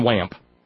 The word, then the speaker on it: Wamp: half the national news readers are pronouncing his name with a short A, like the interstate on WAMP.